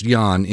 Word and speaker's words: Ian: His name is pronounced Jan, improbably. There's no good explanation for that. It's spelled Ian, but his name is pronounced Jan.